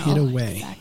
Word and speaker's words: Haida Gwaii: W-A-I. You can see why I didn't know. – Haida Gwaii.